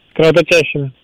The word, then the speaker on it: кровоточащими: Данное причастие образовано от глагола с фиксированным ударением на четвертом слоге. И во всех формах и словах тоже имеет акцент на тот же слог. По-ентому правильно говорить еще раз как? – Кровоточащими.